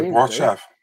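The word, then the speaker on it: Borshchev: They call him Burshov now? Like, what's with the pronunciation? – I say Borchev.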